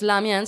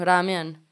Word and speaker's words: ramen: Entonces, y una de esas cosas era el, el fideo, en que en pronunciación japonesa ya no es lamian, sino ramen.